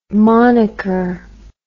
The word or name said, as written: monaker